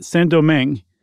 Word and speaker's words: Saint-Domingue: in the French Empire, which was Saint-Domingue, which is now called Haiti, which was one of the most profitable plots of earth on earth at the time. Mm-hmm. I read, Chuck, that Saint-Domingue, and I looked it up.